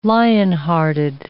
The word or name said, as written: lionhearted